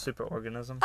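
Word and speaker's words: Superorganism: Oh, super orgasm. – No, it's Super Organism. – Oh, I had no idea that's how you pronounce it.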